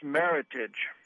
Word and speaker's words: Meritage: The answer is Meritage. – And pronounced correctly.